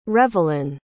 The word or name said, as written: revelin